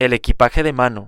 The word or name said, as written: el equpaje de mano